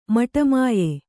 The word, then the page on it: ಮಟಮಾಯ: maṭa māya